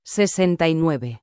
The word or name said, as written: 69